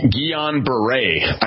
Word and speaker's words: Guillain-Barré: This diagnosis is called Guillain-Barré, I believe that I'm saying that right. Am I saying that right, Dr. Ruby? And how serious is this? – You're saying it right, and I want to thank you for staying on this.